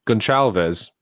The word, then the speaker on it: Gonçalves: Gonçalves.